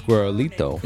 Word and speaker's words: Guerolito: So it's not Guero, which is already out. Yeah. It's Guerolito.